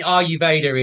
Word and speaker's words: Ayurveda: Ayurvedi. – Yeah, well, there's a few ways. I mean, Ayurveda is kind of Ayurveda. – The classical pronunciation.